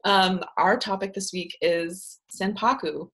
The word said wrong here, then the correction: sanpaku: Who knows if I'm saying it right? – Senpaku? – Sanpaku. – S-A-N-P-A-K-U. Sanpaku. – P-A-K-U. Okay. – Yeah, it's Japanese. – With a san.